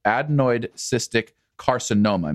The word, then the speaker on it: adenoid cystic carcinoma: adenoid cystic carcinoma.